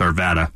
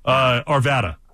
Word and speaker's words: Arvada: It's a sign if you're a local or not, how you pronounce Arvada. – There you go. Arvada.